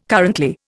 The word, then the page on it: currently: Indian